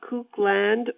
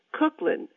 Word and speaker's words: Kuklin: People often mispronounce my last name, calling me Cookland or Cucklin. Nope, it's Cooklin, and it rhymes with Brooklyn. Sometimes when I tell people my last name, I have to spell it, K-U-K-L-I-N.